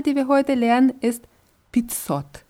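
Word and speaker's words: Pizzot (пятьсот): Also wird geschrieben Pet Sot, ausgesprochen Pizzot. Pizzot.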